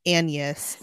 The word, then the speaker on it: Agnes: And the only thing that threw me off is her name is spelled Agnes, but it is pronounced Agnes.